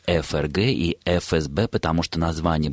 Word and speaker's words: ФРГ: И в результате, Ольга, правильное произношение ФРГ и ФСБ, но так вы практически уже и не услышите.